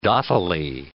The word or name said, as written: dottily